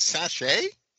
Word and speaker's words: sachet: Is that how you pronounce sachet? It's sachet. S a c h e t.